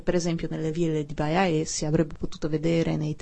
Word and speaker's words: Baiae: che è ambientato a Baiae, come preferisci? Come si dice? Baia? – No, Baiae, Baiae, quello accanto a Napoli.